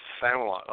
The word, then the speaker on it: Familant: So I'd like to bring Mike on and let's hey, Mike, how do you say your last name? I'm pretty sure I butchered it. – Yeah, you did. it's Familant. – What's that? – Familant. – Familant, okay.